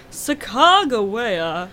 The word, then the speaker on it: Sacagawea: he said it like, Sacagawea. Oh. – I believe that is actually the correct pronunciation. – Oh, dang it. – Wikipedia is telling me there's three correct pronunciations.